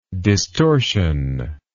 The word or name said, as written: distortionless